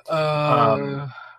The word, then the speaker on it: Grookey: But I quickly was like, well, actually, I kind of like, is it Grookey? Is that how they say it? – Grookey. Grookey. Yes.